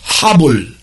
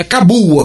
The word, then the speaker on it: Cabul: É assim que se pronuncia: Habul. É, é Cabul, a capital do Afeganistão, que eles lá falam Habul.